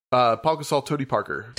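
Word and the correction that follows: Pau Gasol: But no, just is it Paul or Pa Gasol? – It's spelled P A U.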